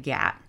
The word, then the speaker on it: Gath: Well, so archaeology from the city of Gat, which that is where Goliath is from, the city of Gath, G-A-T-H. It's typically spelled, you don't pronounce the H at the end.